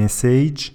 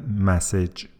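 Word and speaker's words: message: همه میگفتن مسیج و فردای اون روز نمیدونم چی اتفاق چه اتفاقی افتاد چی ریختن تو آب شرب و کل مملکت شروع کرد به درست تلفظ کردن مسج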